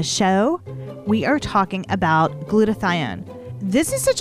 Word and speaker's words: glutathione: So today we go into this powerful antioxidant, which is pronounced glutathione.